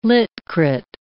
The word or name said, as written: lit crit